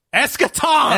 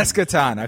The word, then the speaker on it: Eschaton: Eschaton! – Eschaton.